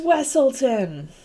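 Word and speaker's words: Weselton: I mean, his entire thing in the movie is just to say, like, it's pronounced Wesselton.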